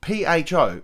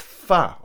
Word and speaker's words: pho: P H O. Some say pho, but it's pronounced "fa," I believe.